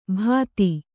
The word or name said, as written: ಭಾತಿ